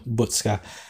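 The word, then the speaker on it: Buksa: And then I think he'll start up top with the man Adam Butzka. I think I say it wrong, because I think I always say bus, and it's not, it's book, sir.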